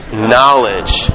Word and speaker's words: knowledge: Starts with a K. The K is silent. Knowledge, right?